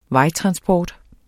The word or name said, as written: vejtransport